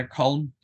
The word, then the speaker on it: Colm: But yeah, Colm, yeah, it's the easiest way of pronouncing it.